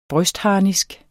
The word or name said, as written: brystharnisk